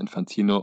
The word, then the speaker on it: Infantino: nee, du hast Infantini gesagt. Ah, uff. la Panini-Sticker. Ähm, Moritz aber er wird, Namen. – Genau. Aber er wird ja natürlich Infantino, unser geliebter Mann, ausgesprochen.